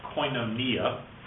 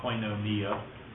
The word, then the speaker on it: koinonia: It's pronounced koinonia. Sometimes people will use that term, koinonia.